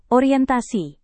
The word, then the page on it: orientasi: Indonesian